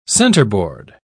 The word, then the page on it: centreboard: British English